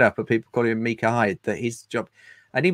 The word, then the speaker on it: Micah Hyde: And he pointed at the team sheet and said, that for Watford is pronounced Micah Hyde. It's Micah Hyde, not Mika.